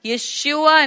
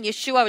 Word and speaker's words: Yeshua: So when a man claiming that he was sent by God, and he had come in the name of Jesus, Yeshua in his original pronunciation, Yeshua, which means what?